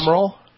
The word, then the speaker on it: bicameral: In a bi-caramel, in a bipartisan way, was done in a bipartisan, bi-caramel way. Bi-caramel, bipartisan support. – Bi-caramel. You must have the Christmas. – Bi-cameral? – Yes, that would be the appropriate pronunciation for meaning both the House and the Senate.